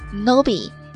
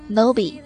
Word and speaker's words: knowbie: 它的英文表达是 Nobie, K-N-O-W-B-I-E, Nobie。还有一种叫做 Newbie, N-E-W-B-I-E, 菜鸟、网络新手。